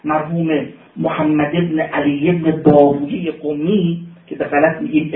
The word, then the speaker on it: بابویه: پسوند اویه پسوند پارسی است. قولویه، شیرویه، سیبویه، راهویه، خالویه، بابویه، اینا رو در تلفظ عربی ویه میخونیم، در تلفظ فارسی اویه باید بخونیم، به معنای رایحه و به معنای ماننده.